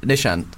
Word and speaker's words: Nishant: Joel likes to vary up whatever the second syllable of my name is. – Nishius. – No, Nishant.